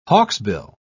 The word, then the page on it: hawksbill: British English